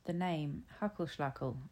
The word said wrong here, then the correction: Hagelslag: when she presented them to me, and her son gleefully corrected me. Hagelslag! I said it incorrectly again, and he corrected me again with gusto. It's Hagelslag!